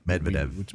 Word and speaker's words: Medvedev: And as, you know, Dmitry Medvedev, I can never say his name. Medvedev. That's right. You've got to slow it down. – Medvedev.